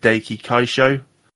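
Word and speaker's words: Daiki Kasho: So I am gonna butcher the pronunciation of this, but is it Daiki Kaisho? Yeah, that's exactly the name.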